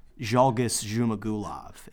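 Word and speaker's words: Zhalgas Zhumagulov: He cashed with a first round KO over He calls him Borat, but I believe his name is Zhalgis Zhumagulov is his name. – Great pronunciation.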